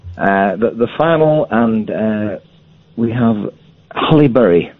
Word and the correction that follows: Halle Berry: I've never heard, and the fact that you mispronounced Halle Berry's name so